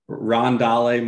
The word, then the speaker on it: Rondale: It's actually, it's pronounced Wandale Robinson and Rondale Moore.